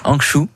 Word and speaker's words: Hangzhou: Hangzhou, Hangzhou. Hangzhou, très bien.